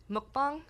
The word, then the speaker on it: Mukbang: Mukbang. – Could you spell that, or phonetically spell it? – Yeah, so it's M-U-K-B-A-N-G.